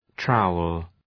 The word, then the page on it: trowel: ‘traʋəl